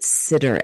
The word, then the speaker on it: Sidr: Long story short, he had a dream about this, I think you pronounce it Sidr, S-I-D-R, tree, and it was growing alone in the desert.